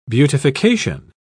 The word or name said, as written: beautification